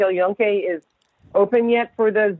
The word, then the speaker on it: El Yunque: So, and I remember just being, I did the El Yunque, I think, is that how you pronounce it? El Yunque Rainforest? – Exactly.